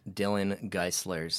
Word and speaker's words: Dillon Geyselaers: Or you can follow me at Dillon Geyselaers.